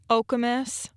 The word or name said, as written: Okemos